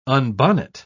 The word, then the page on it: unbonnet: British English